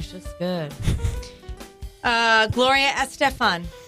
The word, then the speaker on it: Estefan: This is how I've heard it pronounce it. – Oh, sorry. – I've actually heard Estefan. – It from her. – Estefan. – Now, how do you say it? I asked her.